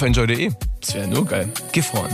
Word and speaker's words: Gifhorn: Heute geht's für Lars mit dem Tandem von Gifhorn nach Hannover. Habe ich es richtig ausgesprochen? Gifhorn, ja.